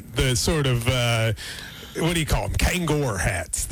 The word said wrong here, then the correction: Kangol: Kangol. Yeah, he's in. – Did I say it wrong? – Kangol hats. You said Kangor.